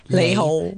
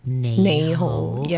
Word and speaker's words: 你好: 你好 Yeah, that's the proper way to say it, because a lot of the time the lazy way to say it is 你好 Yeah, so it's L and N.